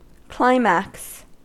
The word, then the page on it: climax: American English